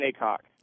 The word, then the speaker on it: Cocke: Patton Acock is my full name. P-A-T-T-O-N. My middle name is Alexander. My last name is C-O-C-K-E, pronounced cock.